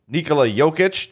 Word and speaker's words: Jokic: They'll take on the winner of the 8-9 matchup, Car Fridge, which had a 64% victory over Nikola Jokic. Did I say that right? – Mm-hmm. – All right, yeah.